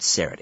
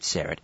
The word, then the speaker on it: Syrett: At Richard Syrett is the Twitter feed, and the last name is spelled S as in Simon. Why? Because I love you. R E T T. At Richard Syrett.